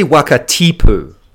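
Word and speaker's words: Wakatipu: And so Waka Tipu is a place that I'd lived right beside in Queenstown and didn't realize the pronunciation of the A's in Waka, which I was taught last week, which... thanks. – You're welcome. – We're all learning. – You're welcome. It's actually Waka Tipu.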